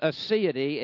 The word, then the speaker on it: aseity: I mean, how often do you use aseity in your daily language? Aseity, A-S-E-I-T-Y.